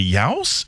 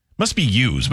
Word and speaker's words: youse: Yows? Is it use? Must be use, but it's spelled Y-O-U-S-E.